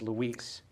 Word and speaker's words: LUIS: She said, I want you to use Luis, pronounce Luis.